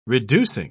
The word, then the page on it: reducing: British English